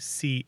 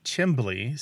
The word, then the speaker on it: chimley: That pronunciation, the C-H-I-M-L-E-Y, and another one, chimbley, C-H-I-M-B-L-Y, are both really common in the United States, and they're often looked down upon. But from another perspective, they're these artifacts of immigration, because they tend to pop up where Americans have roots from the north of England and Scotland, because that's where those pronunciations come from. They go back hundreds of years, heck, as far back as the 15th century in the British Isles.